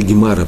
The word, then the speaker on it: Гемара: Гмара или Гемара по- по-русски есть. И так, так говорят, есть два варианта произношения. И там же,